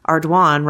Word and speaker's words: Ardoin: I live in Lake Charles where Pizzolatto grew up and that is a really common surname, but it's pronounced Ardoin, rhymes with swan.